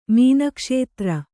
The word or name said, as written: ಮೀನಕ್ಷೇತ್ರ